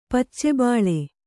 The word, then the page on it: ಪಚ್ಚೆಬಾಳೆ: pacce bāḷe